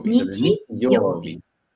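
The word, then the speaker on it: Nichi, Yobi (日曜日): Domingo es día Sol, ¿y cómo se pronunciaría entonces? – Sería Nichi, y le colocas el Yobi. Yobi sería día de la semana, ¿vale? ¿Cómo decir Yobi? – Nichi, Yobi.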